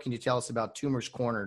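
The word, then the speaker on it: Toomer's Corner: Tumor's Corner.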